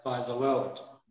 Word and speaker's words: Faza World: Yeah, Faza World.